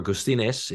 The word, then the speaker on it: Gustines: Actually, the guy who got me into the New York Times, his name is George Gustines, or Gustines, if you do the proper Latin American pronunciation.